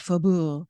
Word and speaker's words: Faubourg: They even tell you on the window, like how to pronounce it. It's a lot simpler than how it's spelled because it's spelled Faubourg. Okay. And I think, you know, just supposed to roll off your tongue like Faubourg.